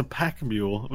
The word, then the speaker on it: pack mule: I like how you called it a packed mule. – A packed mule. He's a packed mule. – But it's a pack mule. But you pronounced the T.